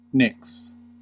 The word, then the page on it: nicks: American English